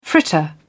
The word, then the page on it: fritter: British English